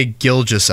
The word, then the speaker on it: Gilgeous: Shai Gildress Alexander. – There's no R in there. Shai Gilgeous Alexander.